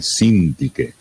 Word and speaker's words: Sintiche: Sempre alla linea 12 c'è Sintiche, mentre alla linea 27 troviamo l'accento sulla prima E, Sintiche. Allora devo ricordarvi un vecchio proverbio,